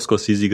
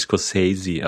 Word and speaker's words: Scorsese: Scorsese gesagt. – Ich hätte auch Scorsese gesagt und nicht Scorsese, aber vielleicht.